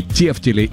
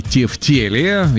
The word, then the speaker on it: тефтели: Можно говорить и тефтели, и тефтели. Есть, конечно, справочник «Русское словесное ударение» имени Зарвы. Он рекомендует ударение на второй слог.